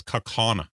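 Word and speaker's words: Kaukauna: So this word, that A after the second K is actually pronounced the same way as the AU. So it's Kaukana.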